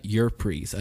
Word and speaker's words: Ypres: This division later then stayed in the Ypres, I believe is how you pronounce it. – Yeah.